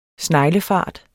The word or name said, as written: SNEGLEFART